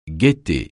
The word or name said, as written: gaieté